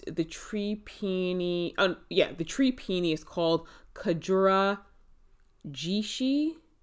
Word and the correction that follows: Kagura Jishi: That's Kagura Jishi.